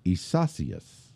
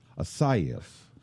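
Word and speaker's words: Isaías: There's the traditional Spanish pronunciation sounds more like Isasius. – Isasius. – Isaias. I say I'm not doing it right. Isaias.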